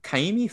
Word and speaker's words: Ka'imi: But this week I have Ka'imi Fairbairn.